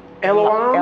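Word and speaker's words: El Olam: Amen. El-Olim. – Elohim? – No. – El-Olam. – El-Olam. El-Olam. You didn't say that right.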